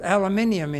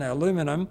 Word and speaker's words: aluminium: Put aluminium into it, or if you prefer the other pronunciation, aluminum, it will become Pyrex for glass, such as we use in the kitchen.